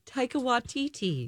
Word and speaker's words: Taika Waititi: Taika Waititi. – Don't what? Taika Waititi.